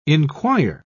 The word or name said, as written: inquire